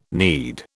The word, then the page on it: knead: British English